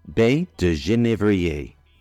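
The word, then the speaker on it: baies de genévrier: Juniper berries in French are pronounced baie de Genève and spelled baie, B-A-I-E-S, de, D-E, Genève, G-E-N-I-E-V-R-E. In French, they can also be pronounced baie de Genèvrier, which is baie, B-A-I-E, de, D-E, Genèvrier, G-E-N-E-V-R-I-E-R.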